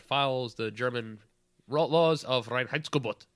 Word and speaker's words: Reinheitsgebot: That's how I've always pronounced it, and that's how I'm going to continue to do it. And you got to have the little emphasis on there, Reinheitsgebot.